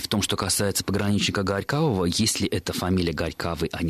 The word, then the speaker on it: Горькавого: Дело в том, что «ого» в русском языке века так с 14-го уже не читается как «ого», и мы как-то говорили о том, что там длительный процесс изменения этого согласного между двумя гласными, и, в общем-то, литературная норма как раз подразумевает пограничника Горькавого, так как и говорят в троллейбусе, в автобусе.